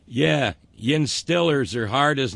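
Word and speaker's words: Steelers: That's how you pronounce it in DeBerg. In Western PA, the vowel sound, long E, as in steel, is replaced by the short I sound of I, as in still, Stillers.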